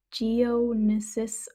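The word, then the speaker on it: Geonosis: I said Genesis, but it's Geonesis or I just can't pronounce it.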